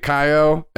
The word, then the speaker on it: Caio: So Tommy's the secretary, Kaio, and that's spelled C A I O, but I looked up the pronunciation, and it's Kaio. I think that's what it is. That's what Google told me.